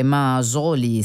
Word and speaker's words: Mazzoli: Missy Mazzoli, oppure Mazzoli, se lo vogliamo pronunciare forse nel modo più americanizzato,